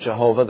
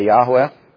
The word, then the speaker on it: Yahweh: And always keep in mind, though we often use the word Jehovah, that is not the correct pronunciation. We should say Yahweh. Y-A-H-W-E-H, and it means Yahweh is salvation.